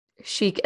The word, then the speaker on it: Chic: Uh, so Rachel founded Chic Events. It's spelled Chick Events, but I will say Chic because that's what it's supposed to be.